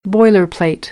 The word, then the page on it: boilerplate: American English; ɚ